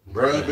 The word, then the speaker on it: Bruh: Bruh Bear, like Brother Bear, but bruh.